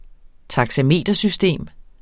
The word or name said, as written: taxametersystem